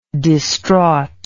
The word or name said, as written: distraughtly